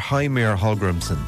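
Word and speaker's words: Heimir Hallgrímsson: Well, it's Heimir or Heimir Hallgrimsson.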